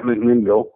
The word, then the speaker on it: McMinnville: Yeah, McMinnville.